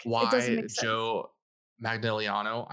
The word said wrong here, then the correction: Manganiello: I know I pronounce his last name wrong every time but why not have him do it? – Manganiello.